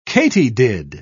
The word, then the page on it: katydid: British English